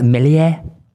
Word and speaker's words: Meslier: I went with Ilan Meslier. – I think it's pronounced Melier.